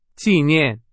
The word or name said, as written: 纪念